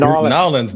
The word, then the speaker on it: New Orleans: How should I be saying it? Because I'm terrible at pronunciation. – Nolens, bro. – Nolens. Okay, good.